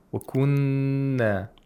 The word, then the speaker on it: Wakunna: Wakunna, we have a noon with shadda. You have to, in fact, apply the ghunna for two vowel counts on the noon. Wakunna.